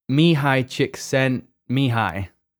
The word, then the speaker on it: Csikszentmihalyi: And so someone I want to reference here is Mihai Csikszentmihalyi. That's how you say his name. I checked before we recorded this.